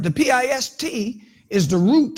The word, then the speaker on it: pistis: The word for faith is the Greek word pistis, P I S T I S, if you want to spell it with, with our English characters.